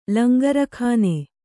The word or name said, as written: ಲಂಗರಖಾನೆ